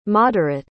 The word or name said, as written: moderate